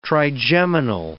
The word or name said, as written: trigeminal